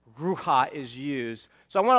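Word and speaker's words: ruha (רוּחַ): That made it sound funny, but that's how you pronounce it. It's ruha.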